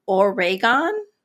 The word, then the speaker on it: Oregon: It's pronounced Oregon. Oregon. Oregon. Not Oregon. Oregon. Oregon. Oregon. It's very easy.